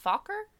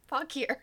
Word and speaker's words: Fauquier: Not Fokker? – Nope. Fauquier.